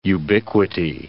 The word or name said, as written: ubiquity